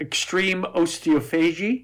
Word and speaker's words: osteophagy: In researching this episode, I learned a new word, extreme osteophagy. Is that correct? Did I say it right? – Yeah.